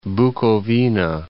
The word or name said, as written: Bucovina